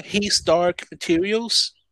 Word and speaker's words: His Dark Materials: His Dark Materials.